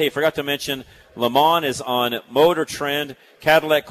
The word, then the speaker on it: Le Mans: And I've always said that Le Mans, but I think the pronunciation is supposed to be Le Mans.